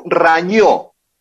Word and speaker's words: Rañó: El club Rañó, r a N o con acento en la O, rarísimo también.